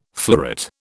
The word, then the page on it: fleuret: British English